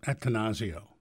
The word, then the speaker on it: Attanasio: Attanasio. – Attanasio. I'm sorry, I pronounced it wrong.